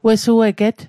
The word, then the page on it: wesueget: Mi'gmaq